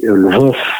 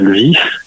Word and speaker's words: Lviv: Kiev, hein, je précise à chaque fois il y a les deux orthographes, hein, faut pas oublier que l'orthographe russe fait prononcer Lvov, Kiev et Kharkov, et donc en ukrainien c'est bien Lviv, Kiev et Kharkiv.